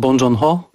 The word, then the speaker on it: Bong Joon-ho: Ja wymawiam Bong Joon-ho i pamiętajmy, że Bong to jest jego nazwisko, Joon-ho to jest imię.